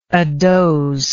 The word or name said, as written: adoze